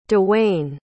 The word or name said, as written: dewayne